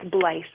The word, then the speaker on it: Bleich: It's Bleich, long I.